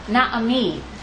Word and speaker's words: Naomi: But also, from the proper pronunciation of her name, what else do you hear in her name? Na'ami. Ami.